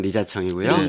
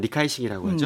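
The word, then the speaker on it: 리자청이고요 (李嘉誠): 그다음에 이제 중국어로 하면 리자청이고요. – 리자청. – 네. 뭐 얼마나 리자청. 대단한 사람인지는 솔직히 모르겠어요. – 네. 홍콩에 사는 리카이싱이라고 하죠. – 맞아요. – 네. 홍콩 발음은 그런데요.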